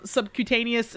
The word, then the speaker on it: subcutaneous: how to give them subcutaneous, is that how you say it? – Yeah.